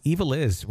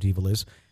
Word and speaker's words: Ivalice: She explains that you pronounce it as Evil Is. – Oh, this is it. And funny enough, it's pronounced like Evil Is. If I just kept reading. Evil Is.